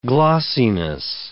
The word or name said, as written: glossiness